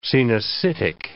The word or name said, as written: coenocytic